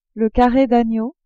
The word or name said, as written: carre d'agneau